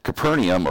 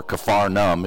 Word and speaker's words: Capernaum: Capernaum, or Capernaum, or Capharnaum, if you want to use a modern saying how they pronounce it today, still there, of course, on the north shores of the Galilee.